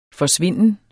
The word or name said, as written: FORSVINDEN